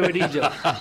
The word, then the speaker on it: verija: Se escribe verija, pero es verilla.